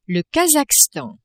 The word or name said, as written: Kazakhstan